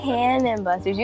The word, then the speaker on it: Cannon Busters: Is it Cannon Busters or Ganon Busters? I've been saying it wrong. – Cannon Busters. You actually had me like look it up. I'm like, wait. – Cannon Busters. Okay. I don't know where the G came from.